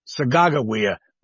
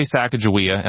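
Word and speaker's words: Sacajawea: I say Sacagawea, and I use the J to spell it because the Shoshone people that I worked with on this book, that's what they prefer.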